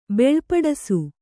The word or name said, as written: ಬೀಳ್ಪಡಿಸು